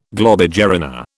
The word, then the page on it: globigerina: British English